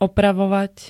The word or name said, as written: opravovať